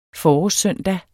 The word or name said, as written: forårssøndag